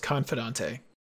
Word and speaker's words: confidante: Ben, it's actually pronounced confidante.